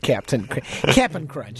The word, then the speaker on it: Cap'n Crunch: And probably cereal number two that he would recommend would be Captain Crunch. That's it, Cap'n Crunch.